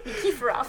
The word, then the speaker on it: Nikiforov: I forgot an entire syllable. – Yeah, I'm reading the name and you didn't say it. – Nikiforov.